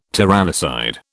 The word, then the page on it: tyrannicide: British English